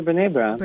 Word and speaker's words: Brené: And then I've read stuff by, is it Brené? Is that how you pronounce her name? Brené Brown? – Yeah, Brené Brown.